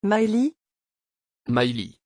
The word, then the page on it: Mailie: French